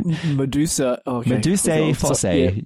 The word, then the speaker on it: Medusae Fossae: Medusae Fossae. I hope I said that correctly. All right. – Medusae Fossae.